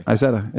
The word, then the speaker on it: Isetta: Now, when you say it with the Z, is that because that's the way you say it in Germany? – Isetta, it's just how you say it. – Okay. – Isetta, yeah.